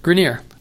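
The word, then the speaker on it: Greiner: do you pronounce her name? – Grainer. Lori How old? – Grainer.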